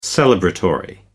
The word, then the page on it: celebratory: American English